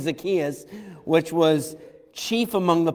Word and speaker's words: Zacchaeus: Brother Bauman, give us the correct non-American pronunciation of Zacchaeus. Okay, all right. I'm going with Zacchaeus. You're right, you're good.